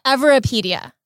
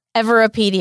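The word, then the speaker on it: Everipedia: Everpedia. – Everpedia.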